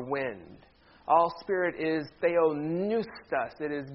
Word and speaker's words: Theopneustos: Everybody say Theopneustos. The P is silent. Theopneustos is actually a compound word, two Greek words put together.